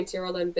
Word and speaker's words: Tyrolin: Yeah, because I look, I, I, do love my name Tyrolin, but it's very rare when people pronounce it properly. It's always Tyrolan I get, or people just don't even try; they just go T.